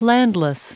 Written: landless

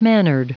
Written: mannered

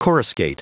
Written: coruscate

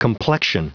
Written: complexion

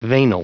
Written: veinal

